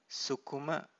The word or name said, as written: sukuma